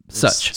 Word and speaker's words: Such: And she said it's pronounced just like Such. – Such. – As Such.